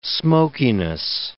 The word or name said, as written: smokiness